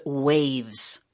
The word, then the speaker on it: Wavves: The name of the band is Waves. And if you're looking them up, check this out. It's spelled W-A-V-V-E-S.